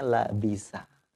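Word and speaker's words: labisa (لَبِسَ): Ya, jadi kita bacanya labasa apa labisa? Ya, kalau artinya bercampur, kita bacanya labasa. Ya, tapi kalau memakai, mengenakan baju gitu, kita bacanya labisa.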